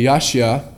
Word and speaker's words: yasha: Now it's interesting that word saved in the Hebrew, it's spelled Y-A-S-H-A, Yashia, is actually the root word for the name Joshua.